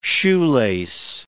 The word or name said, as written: shoelace